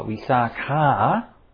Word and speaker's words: Visakha: However, the person there posing questions to Dhammadina was not her former husband Visakha, but the female lay follower, Visakha, with a long A, also known as Migara's mother.